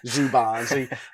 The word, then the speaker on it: Szoboszlai: Szoboszlai.